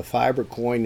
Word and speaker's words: Fibre: I'm still having some troubles here and there with uh, that, but the fiber coin is the correct pronunciation, and uh, they're a, a UK based bunch of guys that have known each other quite a while, had the.